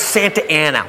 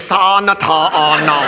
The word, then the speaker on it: Santa Ana: How? It's Santa Ana. What says Santa Ana? Look, I know I didn't finish school, but that frickin' says Sa Nata Ana.